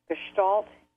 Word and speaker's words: Gestalt: and he had an approach to dreams pronounced Gestalt, G-E-S-T-A-L-T.